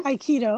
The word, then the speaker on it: Aikido: Is that how you say it? Aikido? – Aikido. Uh-huh.